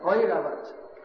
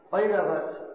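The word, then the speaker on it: Airavata: And Aravat, Aravat, the elephant of Indra, what's the correct pronunciation? Oiravat. Oiravat was spraying water on Indra from the Jamuna.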